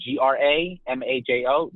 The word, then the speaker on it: Gramajo: And my last name is spelled Gramajo. Literally sounds like Grandma Joe, except without the E at the end.